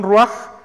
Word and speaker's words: ruach: Algunos han escrito la palabra ruaj como R-U-A-C-H, ruach, ¿verdad? Aunque su pronunciación ruaj creo que es la más cercana al original.